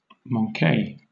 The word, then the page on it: manque: British English